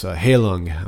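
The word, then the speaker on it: Heilung: Am I saying that right? Halund. Let me check on that. But they're going to be soundtracking the new Hellblade game. – Sweet. – Senua's Sacrifice. Halung. I'm sorry.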